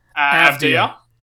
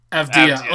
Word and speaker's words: Avdija: Advia. – Avdija.